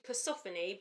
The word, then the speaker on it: Persephone: Okay, so the name of this goddess is actually Persephone. – Persephone, that's it. – But to remember how to spell it... – Yeah, it's like... – I say Persephone because I always get an O and an E in the wrong place. So I say Persephone, yeah. So I spell it correctly, but it's pronounced Persephone, mm.